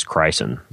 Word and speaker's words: Chrysin: And the one you'd want to look into is Crysin. It's spelled Chrysin.